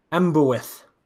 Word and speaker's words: ambiwlans: L-A-N-S. – L-A-N-S. Okay. A-M-B-I-W-L-A-N-S. – Yeah. – All right. Let's go with Ambwyth.